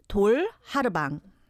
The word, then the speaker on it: Tol Harubang: It's four syllables. Tol Harubang.